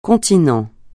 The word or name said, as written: continent